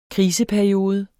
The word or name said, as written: kriseperiode